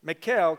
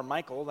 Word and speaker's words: Michal: I'm going to go with mine, okay? Michal, because I don't want to call her Michael, that's weird.